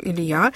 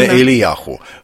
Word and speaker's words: Илия: Я говорю «Илия», вы говорите «Илий». – Илия. Ну, Илия, как правильно, Ильяху. Но мы же не будем с вами так говорить, правда? Мы говорим по-русски, а не на иврите, поэтому...